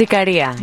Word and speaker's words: Ikaria: Ikaria, is that right? Is that the town? – That's Ikaria, yep.